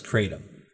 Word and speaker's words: kratom: I'm aware there's different pronunciations. – Yes, I have looked into this, so not extensively, but um, from where I heard it, they said it was kratom.